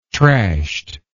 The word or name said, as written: trashed